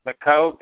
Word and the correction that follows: McKeogh: McKeogh. – I think it's Keo, Megan McKeo.